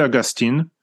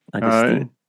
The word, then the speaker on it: Augustijn: How would you pronounce that in Dutch? Is that Dutch or is that Flemish? – That would be Flemish, which is close to the Dutch. I would say Augustine.